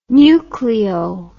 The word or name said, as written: nucleole